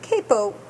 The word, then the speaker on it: capo: A capo, which is the American pronunciation of capo, is a device that's attached to the neck of the guitar in order to change the tuning.